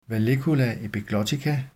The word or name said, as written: vallecula epiglottica